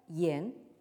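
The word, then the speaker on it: yan (言): se prononce donc en chinois moderne « yan ».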